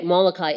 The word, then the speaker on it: Molokai: In 1873, Joseph Damien went to Molokai. Is that right? Molokai. I watched the movie and I still can't get the name right. Molokai? Okay, Molokai in Hawaii.